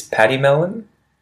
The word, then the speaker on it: pademelon: The next animal after kookaburra is paddy melon. Paddy melon. And paddy melon is spelt P-A-D-E-M-E-L-O-N.